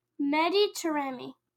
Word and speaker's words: Mehdi Taremi: Mehdi Taremi.